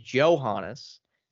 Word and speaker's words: Johannes: And I'm surprised, as I heard the broadcaster say it, so I guess I will repeat it. It is Johannes. I'm surprised that's the way they pronounce her last name.